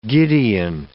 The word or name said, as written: Gideon